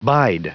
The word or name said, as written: bide